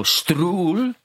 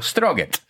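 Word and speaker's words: Strøget: So Strul, which is, you spell it Strogeet. So you look at it first and you go Stroget. And then you go Strul.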